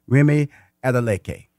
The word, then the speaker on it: Remi Adeleke: Please welcome to Money Making Conversation the author of Transform, Remi Adeleke.